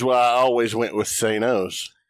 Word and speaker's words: Thanos: And I'd say Thanos. That's how I've always pronounced it, Thanos. – Yeah. – But Josh has a different pronunciation of it. – Thanos. – I have always said it Thanos since the 90s.